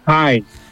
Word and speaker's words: Kain: Hacer el caso que es K-A-I-N, Kain. – Kain. – Kain, con N al final, Kain. – Kain. – Y Lust. – Lust. – Kain Lust.